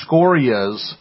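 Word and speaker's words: Sciorra: Scoria? – I believe so.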